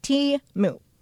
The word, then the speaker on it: Temu: It's called Temu, pronounced Temu, but spelled T-E-M-U.